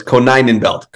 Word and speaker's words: Konyenbelt: It's I always say it's a really easy way to remember. You do a Ko, the number nine, a Nen, and a belt. So it's Konyenbelt.